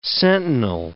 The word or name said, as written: sentinel